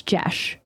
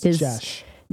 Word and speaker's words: Geas: Yeah, I actually, it's hard G, E like bed. It's Jesh. – It's Jesh. – Jesh, or according to Chris Perkins, it could also be pronounced as, what was the other way that he said, like gas? – I can't with this shit.